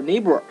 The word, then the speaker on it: neighbor: next 它这个主要是来自于，我再给大家举个例子，就是 neighbor 这个单词，你会发现 gh 根本就不发音，是吧？